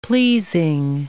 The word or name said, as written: pleasing